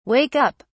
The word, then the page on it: wake up: British English